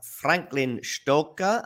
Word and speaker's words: Franklin Stoker: I have Franklin Stoker, and I don't know if I'm saying that right.